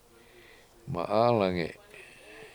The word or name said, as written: ma7Alange7